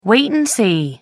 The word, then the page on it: wait and see: American English